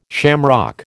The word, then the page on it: shamrock: American English